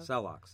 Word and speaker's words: Celox: Do they prefer the pronunciation Celox, though? – Celox, yes.